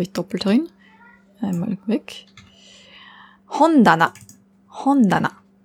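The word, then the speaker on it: Hondana (本棚): Hon ist das Buch, Regal heißt Tana. Aber nur wenn es alleine steht, dann spricht man es Ta aus. Steht es zusammen in Kombination mit dem Hon, dann ändert sich die Aussprache auf Da. Hondana.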